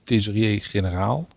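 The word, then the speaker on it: thesaurier-generaal: Kijk, Trip was sinds 1916 de hoogste ambtenaar bij het ministerie van Financiën, tesorier-generaal. Oh, zo spreek je het uit.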